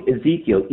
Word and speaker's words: Ezekiel: Maybe I'm pronouncing the name wrong. Ezekiel, easy. – Yeah, no, you're not pronouncing it wrong.